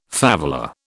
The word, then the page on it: favela: British English